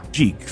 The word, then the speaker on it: Jekyll: If you pronounce it Jekyll, suddenly it rhymes with seek. Yeah. Jeek, seek, like seek all.